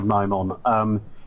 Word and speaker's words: Nimon: I mean, I think Ill of the Dead, but if you take Horns of Nimon, if I can just correct your pronunciation there, if you take the Horns of Nimon, you have that sort of farting noise that you get on the horn.